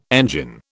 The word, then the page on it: engine: British English